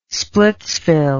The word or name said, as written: splitsville